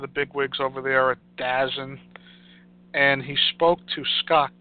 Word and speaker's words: DAZN: I know it's not DAZN, it's DAZN, okay? I don't know why they're pronouncing it as DAZN, it's DAZN.